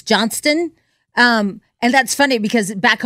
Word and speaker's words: Johnston: Tomorrow, Buccee's will host a hiring event for their new location right here in Johnston. They plan to... – Johnston. – Yeah, because it's spelled Johnstown. – Yeah. And so, but it's Johnston.